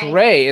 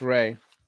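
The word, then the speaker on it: Rai: No, it's no, it's Ray. It's R A I. It's it's pronounced Ray.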